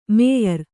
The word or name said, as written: ಮೇಯರ್